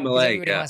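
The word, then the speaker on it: Milei: You got to go back there and do Malay. Is that how you pronounce it again? – Yeah, Malay.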